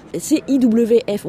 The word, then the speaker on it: CIWF: CIWF, on prononce bien comme ça ? – Tout à fait, c'est l'abréviation de Compassion in Wild Farming, une ONG internationale dédiée au bien-être des animaux d'élevage.